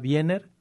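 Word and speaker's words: Gabriela Wiener: La autora es Gabriela Viener, se escribe con W, o sea, se pronuncia con B, pero es W.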